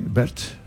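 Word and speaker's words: Baird: Bueno, es, a ver si lo digo bien, es Julia Bert. Sí, Bert.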